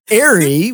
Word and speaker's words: Ary: But Mary without the M, like it's not hard. – It is Mary without the M. Well, that now I can remember. Well, Ari,